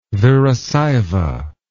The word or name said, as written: virasaiva